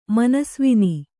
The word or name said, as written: ಮನಸ್ವಿನಿ